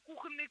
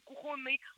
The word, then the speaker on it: кухонный: А вот, например, слова «кухонный» — это раньше была норма, и потом была некоторое время, недолгое время, была двойная норма — кухонный, кухонный. Угу. И сейчас уже определили, что все-таки кухонный победил.